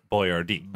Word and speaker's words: Boyardee: Changed the way his name was spelt so people pronounced it Boyardee.